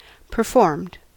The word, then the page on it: performed: American English